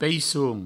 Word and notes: peisson: peissoun; provencal

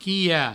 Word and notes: quilhar: provençal; quiha

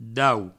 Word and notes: daut: Provençal

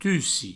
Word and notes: tussir: tussi

